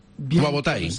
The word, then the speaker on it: Wabotai: D'entrada, dono la benvinguda al Jean-Paul Wabotai. Com estàs, Jean-Paul? – Molt bé. – No sé si he dicho muy bien tu apellido. Wabotai. ¿Lo he dicho bien? – Sí.